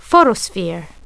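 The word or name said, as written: photosphere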